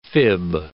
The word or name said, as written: fib